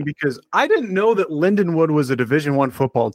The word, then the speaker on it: Lindenwood: What do you know about Linwood? – I know that they're pronounced Lindenwood. – Oh, yeah, sorry. My bad.